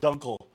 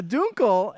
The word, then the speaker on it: Dunkel: It's pronounced Dunkel. – No, he says, I want to thank you for pronouncing it correctly. It's a Dunkel.